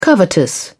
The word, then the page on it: covetous: British English